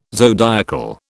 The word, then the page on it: zodiacal: British English